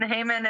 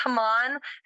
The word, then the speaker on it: Haman: For the English pronunciation, Haman is the English pronunciation. But when you say it in Hebrew, it is Haman.